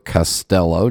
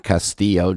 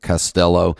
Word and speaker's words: Castello: It's Sergio Castello, just like the pipes, not Castillo, just like the Spanish castle, so it's Castello.